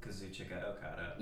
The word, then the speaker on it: Kazuchika Okada: Kazuchika Okada.